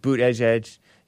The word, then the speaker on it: Buttigieg: Pete Buttigieg. That's how you pronounce his name. Not what Jesse says. Jesse says it's something different. JLP show. Phony. He doesn't really care about us. Pete Buttigieg.